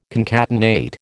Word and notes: concatenate: American English

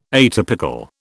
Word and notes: atypical: British English

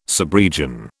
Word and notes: subregion: British English

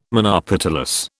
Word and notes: monopetalous: British English

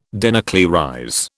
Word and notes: denuclearize: British English

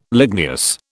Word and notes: ligneous: British English

